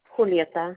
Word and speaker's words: Julieta: Julieta. – Can you spell that? – J-U-L-I-E, T as in Tom, A.